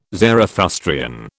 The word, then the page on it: Zarathustrian: British English